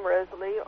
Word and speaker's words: Rosalie: Married name is Arnoff, A-R-N-O-F-F as in Frank, Arnoff. First name, Rosalie, R-O-S-A-L-I-E.